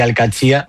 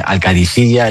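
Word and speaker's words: Al-Qadsiah: Bueno, se suele decir Al-Katzia, pero también hay gente que dice Al-Kadisiyya. Es que es difícil, ¿eh? Ah, no es fácil, pero Al-Katzia es lo más fácil.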